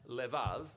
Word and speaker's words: levav: We're going to look at the fourth key word in this prayer, heart, which in Hebrew is sometimes pronounced levav, or more often in a shorter form, lev.